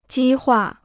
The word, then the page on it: 激化: jī huà